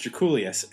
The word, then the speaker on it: Drakoulias: He produced the first Black Crowes album, worked with a lot of bands, and this is how I figured out how to pronounce his last name, Jay, Draculius, because in the Beastie Boys song B-Boy Bouillabaisse, which is on the album Paul's Boutique, they actually name-check George Draculius, and this is how they do it.